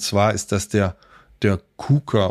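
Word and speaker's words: Quooker: Wie spricht man es richtig aus, Sascha? – Kuka. – Kuka. Kuka. Ja, so. – Das ist holländisch, das weiß man doch.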